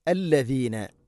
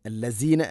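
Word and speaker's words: അല്ലദീന (الذين): അല്ലദീന, അല്ലസീന അല്ല, അല്ലദീന ആമനുത്തഖുല്ലാഹ്.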